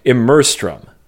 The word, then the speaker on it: Immerstrum: No, there's not another syllable. Immerstrum. I think.